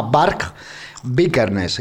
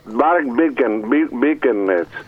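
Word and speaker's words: Varg Vikernes: Lo ha dicho usted, lo ha pronunciado de una manera muy rara, porque él se llama Bark Bickernes y era un noruego. – Bueno, pues, Bark Bickernes.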